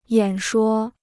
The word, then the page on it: 演说: yǎn shuō